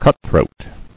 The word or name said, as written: Cutthroat